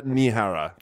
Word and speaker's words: Nehara: No, it's just Isha, I-S-H-A, and second name Nehara. Nehara, I think pronounced in Sinhalese, Nehara, Isha Nehara. – Isha Nehara.